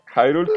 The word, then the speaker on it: Hyrule: Hyrule, que oficialmente se pronuncia Hyrule en, en Latinoamérica.